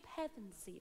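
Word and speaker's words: Pevensie: But they're standing in Caer Paravel, and all of the Pensieve children are lining up. I said that wrong again, didn't I, Jim? Pevensey. Children are lined up, and they're robed, and they're standing before their thrones with Aslan right in the middle.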